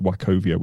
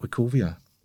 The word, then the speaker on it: Wachovia: I think it's pronounced Wachovia, which I believe is a bank.